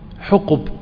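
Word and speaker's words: حُقُب: فيها أي في النار أحقابا، الأحقاب جمع حقب، حقب بضم الحاء والقاف.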